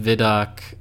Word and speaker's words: ViDoc: Like the, a guy in the post vidoc, how do you say that? Vidoc? – Yeah, I think that's right.